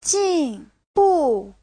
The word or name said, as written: jin4bu4